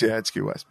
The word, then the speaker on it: Q West: When it's spelled out, it looks like Quest. Like Quest Comedy Special, but it's QS.